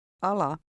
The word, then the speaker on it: ala: Ala. Ala. – The Finnish letter A is pronounced somewhat like the O in hot. Notice that in Finnish the stress is always on the first syllable of a word.